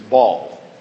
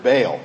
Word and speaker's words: Baal: Now, when we were in Israel, our guide constantly referred to him as Baal. I don't know. Which, have you ever heard it pronounced that way? Baal. Well, he lives over there, and he's more used to the language, so maybe that's right. I don't know. I've always pronounced it Baal, B-A-A-L.